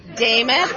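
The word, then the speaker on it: Demuth: I'm going to give you the easiest way to understand that. It rhymes with Joe Namath. Daemmeth, Namath.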